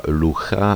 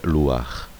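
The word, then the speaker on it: luach (לוח): Ke stejnému vplížení, jako je například jadua místo jada, čili vplížené patach, patach furtivum, a ze stejného důvodu, jako se neříká tapocha, ale tapuach, a lucha, ale luach.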